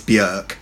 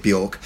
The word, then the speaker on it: Björk: Obviously, the correct pronunciation, because of the umlaut over the O in Icelandic, is Björk, but I've been lazy all my life in pronouncing it Björk, so that will slip in occasionally.